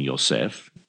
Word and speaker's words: Yosef: And from there, kind of using the manger as a muse, we go back to Nazareth and Mary and Joseph, whom we call Miriam and Yosef, using the actual Hebrew pronunciations of their names,